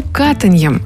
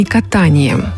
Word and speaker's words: катаньем: Почему катаньем, а не катанием? Объяснение на самом деле простое. Дело в том, что присказка, скорее всего, родилась в тех областях нашей необъятной родины, в говоре которых слово это имело именно такое произношение.